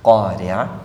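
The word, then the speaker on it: ഖാരിഅ (القارعة): ഖാരിഅ ഖാഫിന്റെ ശബ്ദവും ആയിനിന്റെ ശബ്ദവും, പിന്നെ അതിൻ്റെയൊക്കെ അവസാനത്തിൽ വരുന്ന ഹാത്താവ്, അത് ഹാ ശരിക്കും ഇങ്ങോട്ട് പിന്നെ സുക്കൂനുള്ള ഹാ അവിടെ വ്യക്തത വരണം.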